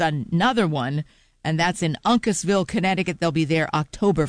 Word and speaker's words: Uncasville: And I always wondered if I pronounced the name of that town correctly, because I've seen it in several towns, or rather several tour announcements with different bands. They like to play at Uncasville, Connecticut. I didn't know if I was pronouncing it correctly. I looked it up, and I am.